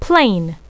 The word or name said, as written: plane